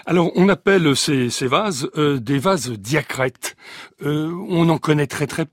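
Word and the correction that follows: diatrète: Oui, alors c'est Diatrette, la prononciation est compliquée, sauf pour les gens de Saône-et-Loire qui ont le R facile.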